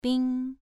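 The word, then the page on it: bing: tone 2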